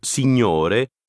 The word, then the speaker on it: signore: Le GN de signore se prononce comme dans le mot digne.